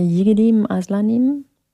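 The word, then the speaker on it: Yiğidim Aslanım: Ich weiß nicht, ob ich es richtig ausspreche. Yigedim Aslanim? – Ja.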